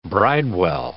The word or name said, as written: bridewell